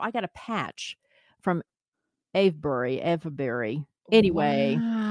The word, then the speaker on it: Avebury: A-V-E-B-U-R-Y.